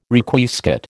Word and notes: Requiescat: American English

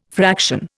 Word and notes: fraction: Indian

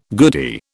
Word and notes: goodie: British English

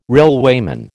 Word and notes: railwayman: American English

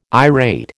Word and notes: irate: American English

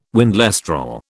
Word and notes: windlestraw: British English

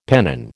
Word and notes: pennon: American English

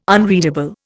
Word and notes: unreadable: Indian

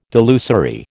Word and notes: delusory: American English

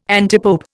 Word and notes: antipope: Indian

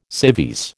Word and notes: civvies: American English

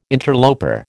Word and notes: interloper: American English

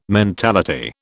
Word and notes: mentality: British English

